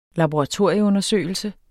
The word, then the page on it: laboratorieundersøgelse: [ lɑboʁɑˈtoɐ̯ˀjə- ]; [ labo- ]